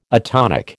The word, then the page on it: atonic: American English